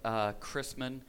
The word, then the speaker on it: Chrisman: My name is Greg Chrisman, spelled like Christ man, and I tell folks all the time that I'm just a man who happened to find Christ.